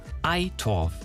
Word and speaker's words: Eitorf: wie Eidorf, obwohl da kein D drin ist. Also es wird ausgesprochen Ei wie das Osterei und Torf wie der Dreck. – Eitorf ist doch gar nicht so schwer.